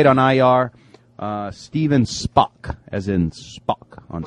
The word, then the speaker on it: Spock: It says Spock, S-P-O-C-K. Spock rhymes with, you know.